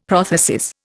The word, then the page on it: prothesis: Indian